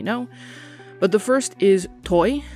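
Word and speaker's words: thôi: I double-checked online, and the way I just said it does seem like the most popular way to say it, so again, toi, and it means stop.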